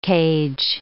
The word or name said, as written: cage